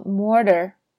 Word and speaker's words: mortar: 那如果是美式英语的发音呢？这个 mortar 翻成 mortar，把那个 t 呢浊化，变成一点像短 d 的声音。